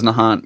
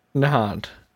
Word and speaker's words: Nahant: And funny story, when I was little, I used to think it was Nahant,